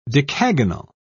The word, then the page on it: decagonal: British English